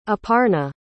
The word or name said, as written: aparna